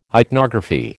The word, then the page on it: ichnography: American English